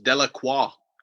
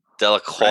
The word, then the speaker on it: Delacroix: I think it's Louisiana near New Orleans, so that's French. Delacroix. – Delacroix.